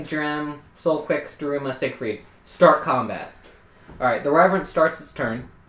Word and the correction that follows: Revenant: Reverent, whatever, however it's pronounced. Revenant.